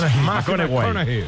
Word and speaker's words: McConaughey: Sí, que hay que ir corrigiendo la pronunciación. – McConaughey.